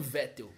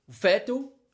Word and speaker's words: Vettel: Nem é assim que fala, né? – É, se a gente for falar no, no alemão mais correto, né? Seria Sebastian Vettel, né? Porque em alemão o V se pronuncia como F.